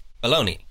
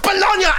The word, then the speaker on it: Bologna: And your inventions are not just baloney. – It's pronounced Bologna, and no, they are not Bologna, certainly not.